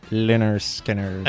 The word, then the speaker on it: Lynyrd Skynyrd: Perché sarebbe Lynyrd Skynyrd. – Ecco qua, vedi che lo... Bravo, lui è bravo, eh.